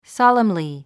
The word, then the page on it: solemnly: sάləmli; ソォレムリィ